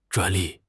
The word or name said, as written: 专利